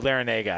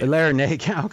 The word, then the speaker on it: Larrañaga: How do you pronounce that? – Is that a uh, Larrañaga, I believe. – Larrañaga. Okay, yeah.